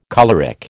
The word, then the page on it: choleric: American English